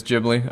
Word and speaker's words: Ghibli: Ghibli. I don't even know how to say that one. – It is Ghibli.